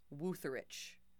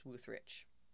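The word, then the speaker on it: Wütherich: He went off and set about preparing the car for the Salinas sports car races with his Porsche mechanic, Rolf Wutherich. Pretty sure that's how you pronounce it. It's W-U with an umlaut. T-H-E-R-I-C-H. So I'm pretty sure it's Wutherich.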